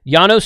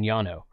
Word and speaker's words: Llano: Incorrect. Llano starts with a double L, and it's a Spanish word, and double L in Spanish is pronounced like a Y.